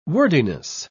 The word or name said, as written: wordiness